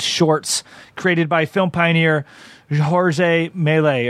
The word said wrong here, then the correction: Georges Méliès: or however you want to. It's Georges Méliès.